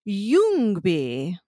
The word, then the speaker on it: Ljungby: And the name of the town, although it's spelled Ljungby, which it's a Swedish word, it's pronounced Ljungby.